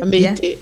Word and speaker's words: Maite: And I would also really like to understand more about your brand, Our Maite. Do I spell it right? – Maite, yes.